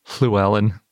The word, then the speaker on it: Llewellyn: But if you speak Welsh, you might be familiar with it, because it's basically pronounced the same as the double L in Welsh, like Llewellyn if you say it in the Welsh way, which I think is something more like Llewellyn, but I don't speak Welsh.